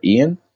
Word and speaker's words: Ian: I'm on on a personal note, I'm on Instagram and Twitter and Facebook creative underscore Ian.